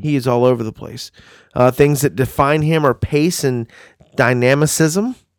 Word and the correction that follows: dynamism: I think I said that correctly, Brian. – Nope, but that's okay. – Dynamism. – There you go.